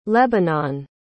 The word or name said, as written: lebanon